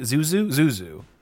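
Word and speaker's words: Xuxa: It's on YouTube or ChadTube, so Zizou, I think Zinu, how do you say it? Zuzu.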